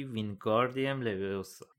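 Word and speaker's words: Wingardium Leviosa: هرماینی با اوقات تلخی گفت داری اشتباه تلفظ میکنی باید بگی وینگاردیم لویوسا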